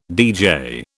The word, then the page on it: DJ: British English